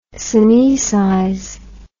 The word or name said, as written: synoecize